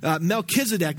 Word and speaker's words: Melchizedek: One says I follow Britain, one says I follow Matt in how to pronounce Melchizedek, okay? But it's Melchizedek.